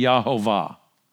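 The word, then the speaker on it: Yahovah (יהוה): And I think the evidence is overwhelming, actually solid hermeneutical evidence that the correct way to pronounce it would be Yahovah.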